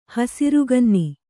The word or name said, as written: ಹಸುರುಗನ್ನಿ